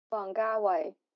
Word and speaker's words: Wong Kar-wai: Wong Kar-wai.